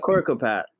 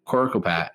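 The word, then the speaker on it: Coricopat: It's Corpocat. Is that how you pronounce it? I always pronounce it. – Corcopat. – Corcopat.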